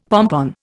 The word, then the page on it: pompon: Indian